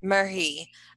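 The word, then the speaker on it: Merhi: How do you pronounce the last name? – Murhi.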